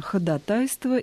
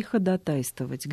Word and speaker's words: ходатайство: «Ходатайство» и «ходатайствовать». Где ударение? – Ударение на втором слоге. «Ходатайствовать», «ходатайство».